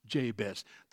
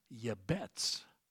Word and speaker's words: Jabez: Now, of course, in Hebrew, that's not pronounced Jabez. They don't have a J in their alphabet. You know how his name's pronounced? Yabetz. Yabet. It's like, well, do you think it's going to be bright and sunny tomorrow? Yabet. Well, so Yabetz, that's his name in Hebrew.